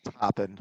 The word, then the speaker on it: Toppin: Toppin.